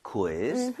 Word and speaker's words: quiz: 우이를 따로 다 발음해야 돼요. – quiz. – quiz. 그 대신에 아. 빠르게 하는 것 뿐이에요. So, quiz 아니라 quiz. quiz. 좀 이런 식으로 퀴 말고요.